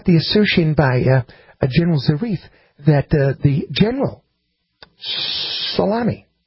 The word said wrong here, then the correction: Soleimani: I'm sorry, I keep saying it wrong. You know I don't mean to say that wrong. – No. – Suleimani.